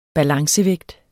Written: balancevægt

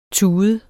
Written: tud

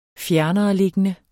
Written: fjernereliggende

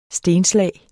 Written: stenslag

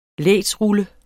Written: lægdsrulle

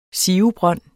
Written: SIVES

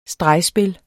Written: stregspil

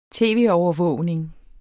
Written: tv-overvågning

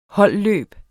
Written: holdløb

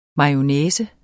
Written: mayonnaise

